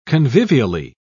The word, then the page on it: convivially: British English